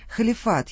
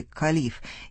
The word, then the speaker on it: халиф: Что такое халиф или калиф? И тот и другой вариант произношения употребляется в русском.